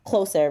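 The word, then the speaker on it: KU LO SA: So Colosa is like pronounced closer, really, or is a pronunciation of the word closer, which is what Oxlade is crooning, that he wants intimacy, he wants connection with this particular person.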